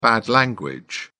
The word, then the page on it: bad language: British English